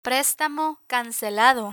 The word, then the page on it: prestamo cancelado: Spanish